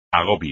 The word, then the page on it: agobio: espagnol